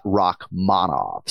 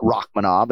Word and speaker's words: Rakhmonov: Yeah, so we're going second syllable here, Shavkat Rakhmonov. So not Rakhmonov, and hopefully that helps everybody with the navigation this weekend.